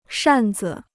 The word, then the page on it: 扇子: shàn zi